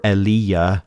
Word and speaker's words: Elijah: So, my God, and then Jah, or depending on how you pronounce the J, it could be a Yah, like Yahweh, right? Elijah or Eliyah, my God, and then the is implied, my God is Jehovah, or my God is Yahweh, or my God is the Lord.